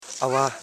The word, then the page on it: awa: a.wa